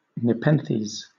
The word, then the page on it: nepenthes: British English